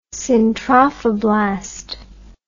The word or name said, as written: syntrophoblast